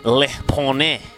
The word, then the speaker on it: Le Poney: I've looked up how you say it in French just so you understand, Izzy, but rumours swirling that you have finally caved and Tilly now owns Le Poney.